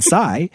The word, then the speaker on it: psi: But that is true because if you take pi, for example, like Alan mentioned earlier, and Trav realized that pi rhymes with phi and psi.